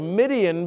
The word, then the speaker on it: Midian: And it's easy to remember the story of Midian because it rhymes with Gideon, all right?